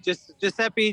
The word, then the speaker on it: Giuseppe: Yes, you did. Giuseppe. It depends on what part of Italy you're from.